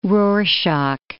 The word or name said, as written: Rorschach